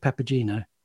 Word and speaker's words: Papageno: So, but you have chosen From the Magic Flute by Mozart, one of Papageno's, is it Papageno? – Papageno, you see, proper snobs like you know how to say it. – Or Papageno. people who can speak Italian.